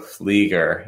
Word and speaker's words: Flieger: There's a flea in Flagger instead of fly. So Flieger. Yes.